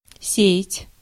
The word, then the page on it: spread: American English; /spɹɛd/